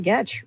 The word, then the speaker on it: Goetsch: I am Sallie Goetsch, rhymes with sketch, the WP fangirl, and uh, you may be graced by a visit from my cats.